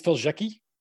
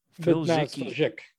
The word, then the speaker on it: Faliszek: So you would really pronounce, well, and when they were coming over, they Americanized the name. So really it's Faliszek. Faliszek would be, and there is actually a couple like still in Poland. We just say Faliszek.